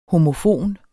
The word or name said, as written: homofon